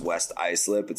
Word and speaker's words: Islip: Pat, it's pronounced West Islip. It's actually a town about 10 minutes from where I grew up. You said Islip, but that's neither here nor there.